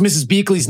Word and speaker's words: Beakley: And Bettina Beckley. No questions asked. – You can't even pronounce Mrs. Beakley's name.